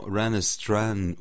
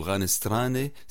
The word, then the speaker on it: RanestRane: Oui, en effet, l'excellente formation Ranestran ou Ranestrane, si on le prononce en italien, ce sera leur toute première visite au Canada.